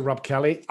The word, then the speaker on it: Robb Kelly: So my name's Dr. Rob Kelly, R-O-B-B-K-E-L-L-Y, spell it with 2 B's for Bravo.